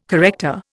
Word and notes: corrector: Indian

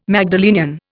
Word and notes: Magdalenian: Indian

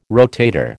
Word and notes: rotator: American English

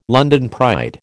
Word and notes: London pride: American English

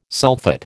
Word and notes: sulphate: American English

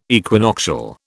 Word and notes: equinoctial: British English

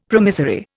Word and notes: promissory: Indian

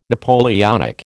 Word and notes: Napoleonic: American English

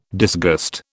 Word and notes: disgust: British English

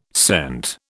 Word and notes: cent: British English

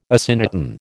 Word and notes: asyndeton: American English

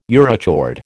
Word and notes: urochord: American English

